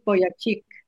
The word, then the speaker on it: Vujicic: hace mención de este señor que se llama Nicholas James Vujicic, creo que se pronuncia. Él es australiano. – Uh-huh. Es Nick Vujicic.